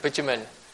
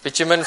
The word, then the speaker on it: bitumen: How do I say that? – Bitumen. – Great. Bitumen for mortar.